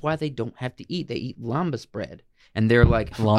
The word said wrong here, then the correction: lembas: My guy, I think it's pronounced lambus bread. – Lambus bread. – It's probably actually lembas.